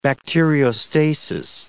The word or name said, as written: bacteriostasis